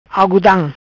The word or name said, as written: Algudam